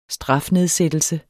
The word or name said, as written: strafnedsættelse